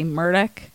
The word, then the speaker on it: Murdaugh: It is Murdaugh or Murdick? – Well, it takes place in South Carolina. And so with the accent, they say Murdick. But it looks like Murdaugh if you're from the more northern parts of...